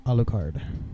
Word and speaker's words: Alucard: Alucard.